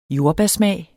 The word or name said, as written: jordbærsmag